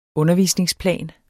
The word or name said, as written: undervisningsplan